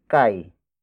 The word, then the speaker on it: kay: Ako, spelled as A-K-O. It means I, in focus form. After that, it's kai. Kai. Kai. Kai is spelled as K-A-Y.